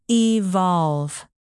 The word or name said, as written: evolve